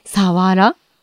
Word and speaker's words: さわら: あの、なんでかというと、まあ、その、漢字で書くと、狭い腹と書いて、さ、はら。それが、なまって、さわらになった。